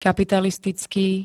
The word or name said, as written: kapitalistický